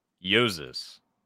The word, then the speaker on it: Jousis: My name is pronounced Jousis.